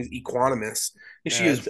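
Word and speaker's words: Equanimeous: Equiminius.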